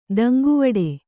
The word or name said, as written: ದಂಗುವಡೆ